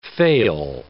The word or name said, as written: fail